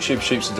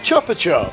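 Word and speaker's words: Chupa Chups: Nobody pronounces it Shupa Shoop. – It's the Shupa Shoops. – No, it's Chupa Chup.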